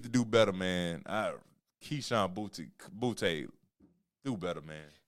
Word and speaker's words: Boutte: But I do know you got his last name right, Boutte.